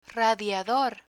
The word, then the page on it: radiador: Spanish